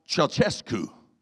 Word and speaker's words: Ceaușescu: Nicolae Ceaușescu. Ceaușescu, I believe, was the way you say his name.